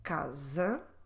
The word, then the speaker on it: casa: So every time you see S between vowels within a word, let's pronounce it like z. I do have here some examples. The first one, casa, casa.